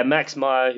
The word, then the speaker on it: Max Meyer: Is that the, did I get the name right on that? – Yeah, Max Meyer,